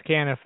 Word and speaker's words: Canniff: Am I saying your last name right? Caniff? – Yes, Caniff. That's correct.